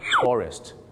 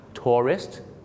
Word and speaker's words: tourist: tourist ส่วนผู้ก่อการร้าย terrorist 2 คำนี้มีเส้นบางๆอยู่นะครับในการออกเสียงคือพยางค์ 2 พยางค์ tourist นักท่องเที่ยวกับ terrorist 3 พยางค์ครับผมน่ะกลัวนะครับว่าคุณจะเป็นเหมือนลูกศิษย์ผมคนนึงครับฝรั่งถามว่า Why did you come to America?